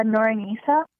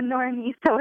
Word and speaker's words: Noranisa: Hi, it's Noranisa. – Yurnisa. – No, Noranisa with an S.